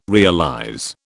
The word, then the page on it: realize: British English